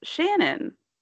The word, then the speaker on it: Shanann: I, I, I just did too, and I saw on her Facebook she pronounced it Shannon.